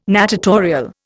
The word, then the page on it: natatorial: Indian